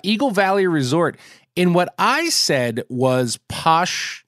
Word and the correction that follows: Pioche: Nevada. And that was supposed to be pronounced Pioche.